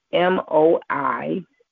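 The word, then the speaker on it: moi (μοι): Here, the Greek word for me is M O I, which is pronounced moi, which means unique to me or for me.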